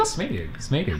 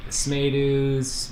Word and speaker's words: Smadu: Smadu.